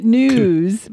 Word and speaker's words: Knewz: Um, website launch today called Knewz News with a silent K K N E W Z.